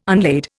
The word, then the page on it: unlade: Indian